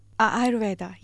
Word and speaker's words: Ayurveda: You come from this heritage where, here's another word where I can't pronounce it, Ayurveda. – Ayurveda, yes, the bush medicine as yes.